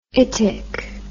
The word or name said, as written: itic